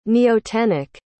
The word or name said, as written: neotenic